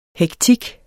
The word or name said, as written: hektik